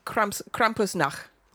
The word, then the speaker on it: Krampusnacht: Well, it's happy Krampusnacht.